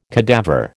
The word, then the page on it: cadaver: American English